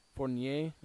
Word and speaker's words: Fournier: Because if, like, you were to just do a swap, for, like, there's been rumors around Evan Fournier, I think that's how you say his name, from the Magic. – Fournier, okay.